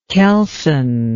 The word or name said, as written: kelson